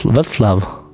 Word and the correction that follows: Vaslav: Ah, Vaslav, pardon.